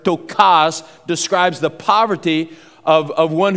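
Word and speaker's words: ptochos: It's got a silent P at the front, P-T-O, and it's a long O, C-H-O-S. Tokas is actually the way you'd pronounce that.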